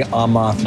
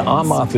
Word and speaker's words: Aamoth: Amoth Jr. And Amoth is spelled A-A-M-O-T-H.